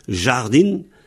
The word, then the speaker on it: jardin: Ça s'écrit J-A-R-D-I-N, comme en français, en fait. Alors, chez moi, on prononce jardin. Alors, le A peut devenir E, souvent. Surtout dans tout le centre-Bretagne, là, le A devient facilement E. Donc, jardin se prononce jardin.